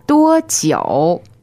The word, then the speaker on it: 多久: 多久, 多久, first tone and third tone.